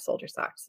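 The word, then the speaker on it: Soledier Socks: It's spelled S-O-L-E, like the sole of your foot, D-I-E-R-S-O-C-K-S. So it's a play on words.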